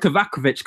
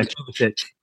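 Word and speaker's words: Kovačić: Obviously, with Kovacic coming in, or Kovacic, how you say it? – Yeah, Kovacic.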